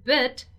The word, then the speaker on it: bit: So the pronunciation of these two words here, B-E-A-T and B-E-E-T, they are very, very similar. Beat, they have a long E sound. So it's something like beat, right? Beat, beat, beat. While the word that we are talking about here today in our dialogue is bit, bit, a little bit, a small bit, a bit of time, wait a bit, okay?